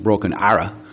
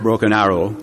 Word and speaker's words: Broken Arrow: Actually, I went to Tulsa, Oklahoma first, and people thought I was crazy, and I worked in a place called Broken Arrow. It's not pronounced Broken Arrow. It's Broken Arrow.